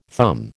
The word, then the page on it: thumb: American English